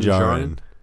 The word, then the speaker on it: Djarin: Din Djarin.